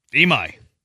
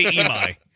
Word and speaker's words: Imai: Imai. – That's how you say it. – You can say su. I say Imai.